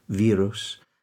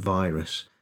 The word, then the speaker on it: virus: Yeah, it's the long I that in a lot of languages just doesn't exist. So people are saying virus and isolation, whereas it's isolation and virus and quarantine, which doesn't have the long I. It has an E sound, quarantine.